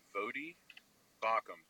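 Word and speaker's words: Voddie Baucham: It's spelled V-O-D-D I-E, Vodi Bauckham, B-A-U-C-H.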